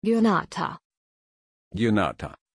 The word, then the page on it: Gionata: German